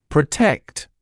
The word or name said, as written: protect